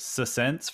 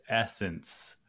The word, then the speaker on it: Essence: In my head, I said it Sessence for the longest time. And then a girl at my work was like, it's Essence.